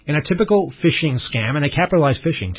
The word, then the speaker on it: phishing: And finally, on the retail business, I guess, I got this from my bank, a whole little pamphlet on what is phishing, spelled P-H-I-S-H-I-N-G. It's a really weird times we're living in. Phishing, pronounced like fishing with the F, isn't it?